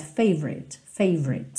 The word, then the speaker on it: favorite: Esse O do favorite não é muito pronunciado, tá? Então, você meio que passa direto, favorite song.